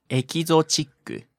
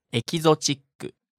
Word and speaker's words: エキゾチック: 普通はどういうふうに言いますかね。 – エキゾチック。 – 全然違いますよね、英語の発音と。 – Wasn't even close. もう一度お願いします。 – エキゾチック。